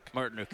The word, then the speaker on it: Martinook: Martinook.